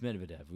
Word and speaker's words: Medvedev: The Dmitry Medvedev disclosure. Medvedev? – It's Medvedev.